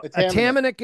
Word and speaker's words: Atamanuik: So Atamanuik is how you say it. Atamanuik. – Okay. – Now that's Canadianized. So it's Ukrainian. So it should be Atamanuik. That's the way it should be pronounced.